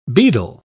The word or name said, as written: beadle